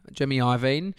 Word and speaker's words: Jimmy Iovine: And Jimmy Irvine... Jimmy Iovine, fucking don't know how to say his name.